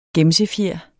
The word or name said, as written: gemsefjer